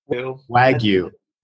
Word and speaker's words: Wagyu: Or as my friend in Honolulu, who has the restaurant that makes the best burgers in the state of Hawaii, likes to call it Wagyu. Wagyu. – That is the proper pronunciation.